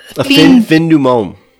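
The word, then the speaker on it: La Fin du Monde: It's La Fondu Mandu. It's a beer they have there. – A fin du monde.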